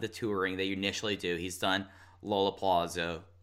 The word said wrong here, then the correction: Lollapalooza: I mispronounced that so bad. – That was fascinating. I've never heard anybody say it like that. – Lollapalooza. Lollapalooza. – Say Lola. Wow. – Lollapalooza. There we go.